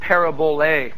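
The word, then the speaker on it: parabole (παραβολή): Now, the word parable is the Greek word parabole, and it's spelled P-A-R-A-B-O-L-E. Parabole, P-A-R-A-B-O-L-E.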